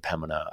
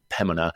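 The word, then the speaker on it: Pembina: Be careful, don't pronounce the B in Pemina. I've been told that on several occasions, Pemina.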